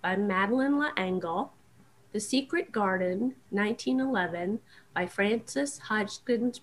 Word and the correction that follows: Hodgson: Burnett. I can't ever say her name. Frances Hodgson Burnett.